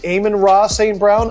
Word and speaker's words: Amon-Ra St. Brown: What about, how do you say his name? Amon-Ra St. Brown? Amon-Ra St. Brown? – I think you nailed it the first time.